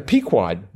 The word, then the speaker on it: Pequod: Okay, now, Pequod. – Boy, the Pequod. Man, I'll tell you. Oh, that's how you pronounce it.